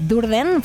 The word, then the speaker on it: Durden: No perdón que lo he dicho mal, el nombre viene de Tyler Durden, el personaje que interpreta Brad Pitt en el Club de la Lucha. Y Durden es el grupo que lidera Diego Losada, invitado en el último típico programa de Radio 3, el último quiere decir el pasado, el del pasado sábado.